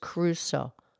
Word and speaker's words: Crusoe: No, it's just Crusoe. Two syllables.